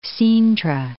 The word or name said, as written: Cintra